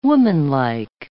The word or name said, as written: womanlike